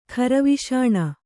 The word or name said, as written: ಖರವಿಷಾಣ